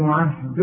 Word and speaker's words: عهدي: قال رأى اللام ومن ذريتي، رأى قوي الذاتي ومن ذريتي، قال برضو رأى اللام لا ينام عهد الظالمين. الغي الياء دي بتاعت عهدي من النطق اذا وصلت، انما تثبت اذا وقفت. قال لا ينام عهدي عند النطق عهد الظالمين، لانها سكنة واللام سكنة.